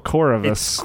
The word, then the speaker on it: Corvus: Corvus. Chorus, But with a V for a U.